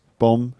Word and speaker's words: bomb: Many of our words have silent letters in them. – Bomb. – There's loads and loads. Bomb, for example. Bomb, B-O-M-B, not bomb.